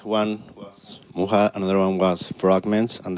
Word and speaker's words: Myxa: For those who aren't familiar, it's spelled M-Y-X-A, pronounced Muha.